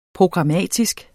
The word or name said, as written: programmatisk